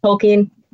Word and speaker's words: Tolkien: It's pronounced Tolkien,